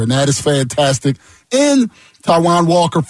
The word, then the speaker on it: Taijuan: But first things first, they get Taijuan Walker, and we found out that it is Taijuan Walker is pronounced like the country.